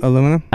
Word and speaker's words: aluminium: Aluminum? – Aluminium. – Wherever you put the syllable, it's cool. – Mm-hmm. Yeah, well, that's how the Australians say it, and they're never wrong for aluminium.